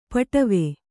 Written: ಪಡಾವು

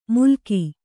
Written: ಮುಲಕಿ

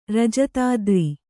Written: ರಜತಾದ್ರಿ

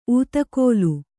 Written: ಉತ್ಕಲಿ

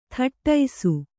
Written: ಥಟ್ಟೈಸು

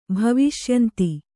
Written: ಭವಿಷ್ಯಂತಿ

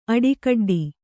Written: ಅಡಿಕಡ್ಡಿ

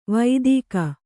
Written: ವೈದಿಕ

